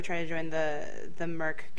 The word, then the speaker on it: MERC: I think it's pronounced Merc, M-E-R-C, which is kind of an acronym for Mos Eisley Radio Community.